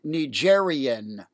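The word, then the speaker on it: Nigérien: I also want to note that the adjectival form, or the designation for a national of Niger, is Nigerian with an I-E-N, as opposed to Nigerian with an I-A-N,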